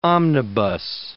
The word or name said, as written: omnibus